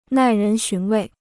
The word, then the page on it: 耐人寻味: nài rén xún wèi